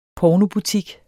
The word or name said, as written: pornobutik